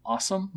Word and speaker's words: OSoMe: Not yet, though they now have Awesome, which is O-S-O-M-E. They pronounce that awesome.